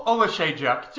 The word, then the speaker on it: Oleksiejczuk: We have Jimmy Crute, the plus 120, dog to Michael Oleksiejczuk. How did I do? – Oh, Oleksiejczuk. Just the emphasis was wrong, but apart from that, you did well.